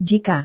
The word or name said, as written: jika